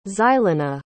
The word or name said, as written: xylona